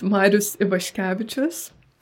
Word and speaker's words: Marius Ivaškevičius: Tell us about the author to begin with, if I'm pronouncing this right. Marius Ivaskevicius. Is that right? – That's a good attempt. It's such a long name, honestly. So it's pronounced Marius Ivaškevičius.